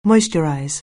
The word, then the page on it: moisturize: anglais